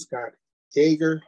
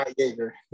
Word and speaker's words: Scott Yager: Today I have a special guest, Scott Yager. I'm saying that right? – Yager, yep. – Yes, yeah, Scott Yager.